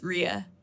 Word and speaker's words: Rhea: It's Rhea. So that's how it should sound.